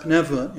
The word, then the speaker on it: pneuma: Pneuma, or some, some people say pneuma, but it's pronounced pneuma in, in Greek.